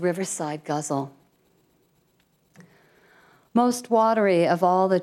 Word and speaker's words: ghazal: It's spelled G-H-A-Z-A-L, but it's really pronounced guzzle, which is kind of a funny word for a form.